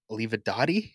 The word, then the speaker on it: Olivadotti: So I don't know what that means for Olivadotti, is that how you say his name? – Yes.